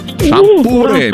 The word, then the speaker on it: шампуры: Итак, запомните, Олечка, и вы особенно, что везде, как бы вы там ни переворачивали, ударение во всех формах существительного падает на букву «у». Шампуры, металлические шампуры, жарить на шампуре, есть с шампура.